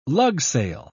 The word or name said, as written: lugsail